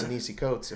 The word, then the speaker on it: Ta-Nehisi Coates: Because it's Ta-Nehisi Coates.